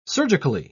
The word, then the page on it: surgically: British English